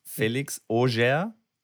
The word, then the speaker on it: Felix Auger: Felix Auger.